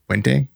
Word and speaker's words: Puente: Puente, yeah.